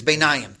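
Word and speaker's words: beinayim (בינים): So my beinim. I'm sorry, I'm not pronouncing it correctly. It's beinayim. Thank you.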